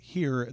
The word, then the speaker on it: hir: Just for the sake of marketing purposes, and I just want to let people know that the show is called Here. That is spelled H-I-R. – So, it's a gender pronoun. – Correct. Mm-hmm. So here, and it's H-I-R.